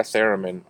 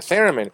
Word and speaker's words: theremin: No, but I would like a theremin. I was gonna buy one, but then I heard of how we can hunt parts down as a quest, because a theremin.